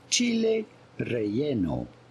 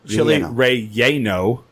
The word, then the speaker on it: chile relleno: How do you go about pronouncing it with a typical Spanish pronunciation? Chile relleno. – Mm-hmm. – Chile relleno. – Chile relleno. – Chile? – Not chili relleno.